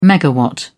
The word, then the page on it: megawatt: British English